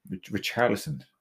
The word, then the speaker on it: Richarlison: I'm going with Carlison. Richarlison. Okay. I said it wrong with CHs.